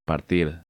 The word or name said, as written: Partir